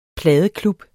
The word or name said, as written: pladeklub